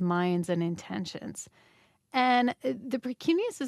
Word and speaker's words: precuneus: Tell me if I'm pronouncing this correctly. The precuneus? – Yes, the precuneus. Very nice.